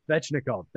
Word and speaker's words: Svechnikov: And then just to round off some of the numbers behind some of the things you said, Aho and Svinchov, I can never say his name. Svechnikov, thank you.